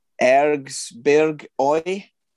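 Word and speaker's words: Erzgebirge Aue: Now here, I don't know why you always give the Scotsman the funny pronunciations, but it's Ergsberg Oi.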